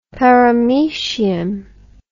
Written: paramoecium

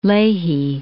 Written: Leahy

